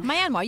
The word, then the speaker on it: Myanmar: Is that am I saying it correctly? Myanmar? – Myanmar, yeah. – Myanmar. – You got it.